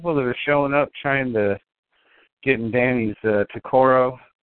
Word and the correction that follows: tokar: Or no, Tokar. That's how you say it.